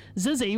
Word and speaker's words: XYZZY: Zizzy, which I'm also glad to know is how you pronounce it now, which is spelt XYZZY for anyone doing a little Google as we speak.